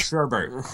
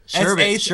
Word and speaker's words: sherbet: Because it's not sherbet. If you spell it correctly, it's okay. – Yes. Is it really? – Yeah, it's sherbet. S-H-E-R-B-E-T.